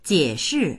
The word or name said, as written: jie3shi4